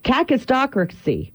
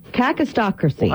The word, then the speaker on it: kakistocracy: Today's word, and I don't know where it comes from, is cacistocracy. Cacistocracy. – What? – Cacistocracy. And let me spell it real quick for you. K-A-K-I-S-T-O-C-R-A-C-Y.